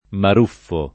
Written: Maruffo

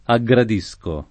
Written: aggradire

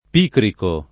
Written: picrico

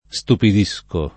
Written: stupidire

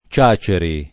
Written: Ciaceri

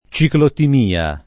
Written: ciclotimia

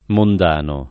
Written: mondano

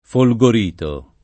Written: Folgorito

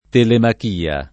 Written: Telemachia

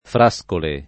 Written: Frascole

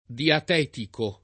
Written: diatetico

